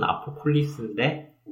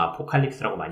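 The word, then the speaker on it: Apokolips: 그 다크사이드라는 애가 아포콜립스, 그러니까 써놓으면은 아포콜립스인데 우리가 읽기로는 아포칼립스라고 많이 읽거든요.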